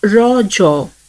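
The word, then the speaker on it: Raja: The festival is natively pronounced as Rajo, a local term that means menstruating woman.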